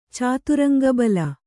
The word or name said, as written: ಚಾತುರಂಗಬಲ